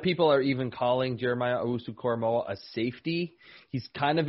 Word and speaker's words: Owusu-Koramoah: It is Owusu-Koramoah, right? Is that perfect. – Right? – Jeremiah Owusu-Koramoah. – Okay, perfect.